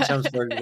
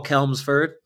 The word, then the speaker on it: Chelmsford: So there was one in Chelmsford where I went, which I used to call Kelmsford.